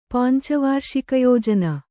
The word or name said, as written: ಪಂಚವಾರ್ಷಿಕಯೋಜನೆ